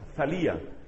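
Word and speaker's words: Thalia: Se escribe con TH, Talía. Talía.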